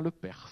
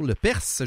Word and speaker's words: Lepers: Monsieur Julien Lepers. – Oh, Lepers, j'ai oublié de prononcer le S.